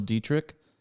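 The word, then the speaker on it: Deitrich: Yeah, I'm on all the social media platforms at Daniel Dietrich.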